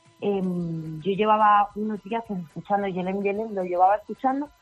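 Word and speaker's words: Gelem: Ese es el Yelem que realmente se escribe con G, Yelem, pero que se pronuncia Yelem.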